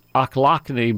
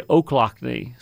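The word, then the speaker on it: Ochlockonee: I used to pronounce this Ochlockney, but a guy said, no, that's really Ochlockney.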